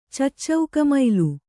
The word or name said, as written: ಚಚ್ಚೌಕಮೈಲು